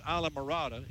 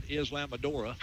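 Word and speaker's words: Islamorada: I learned how to pronounce Isla Mirada. It's not Islamadora.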